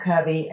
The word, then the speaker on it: Kirby: Because I say, like, hi, my name's Kirby. And, you know, I get Cubby.